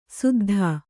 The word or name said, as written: ಸುದ್ಧಾ